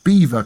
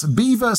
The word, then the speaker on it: Belvoir: Well, perhaps not, but maybe the grandest castle in Leicestershire is Beaver Castle. That's Beaver spelled B-E-L-V-O-I-R, so nothing like how it's pronounced.